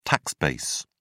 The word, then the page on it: tax base: British English